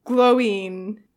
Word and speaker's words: Glóin: Because someone sent me, Ethan was very adamant, and Google kind of proved him right, that it's pronounced Glóin. Glowing, I don't know.